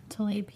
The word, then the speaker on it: Tlaib: Rashida Tlaib? – Tlaib, yeah.